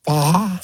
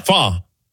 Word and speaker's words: pho: Fa. – Fa.